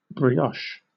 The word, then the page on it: brioche: British English